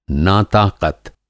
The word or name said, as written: नाताक़त